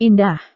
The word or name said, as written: indah